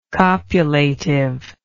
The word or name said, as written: copulatively